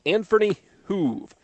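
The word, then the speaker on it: Anferny Hoeve: Josh Haggerty, if anybody can reach, in the starting pitcher on the hill right now for North Central, Anferny Hoove. That's Anferny, A-N-F-E-R-N-Y, last name H-O-E-V-E. Yes, I had to get a pronunciation on that from Coach Hillerman beforehand.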